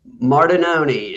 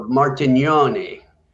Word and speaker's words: Martignoni: Martinoni is the anglicized version of Martignoni.